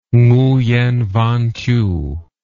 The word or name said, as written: nguyen van thieu